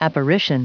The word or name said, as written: apparition